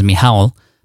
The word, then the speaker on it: Michał: I actually pronounced it Michael for a long time until I finally got to talk to him and realized that his name was Michał, but Michał Safranski.